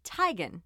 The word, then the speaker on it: Teigen: It's not Chrissy Teigen, it's Chrissy Teigen.